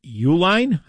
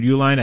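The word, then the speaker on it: Uihlein: The Restore Sanity PAC is funded by Wisconsin billionaire Richard, is it Uline, I believe is a pronunciation, Richard Uline, a top donor to President Trump.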